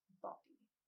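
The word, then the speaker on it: Bawdy: B-A-W. How did you say it was spelled? – It's B-A-W-D-Y. – Okay. – Body. But it sounds like I'm saying body. And I'm not. You know what I mean? – Body.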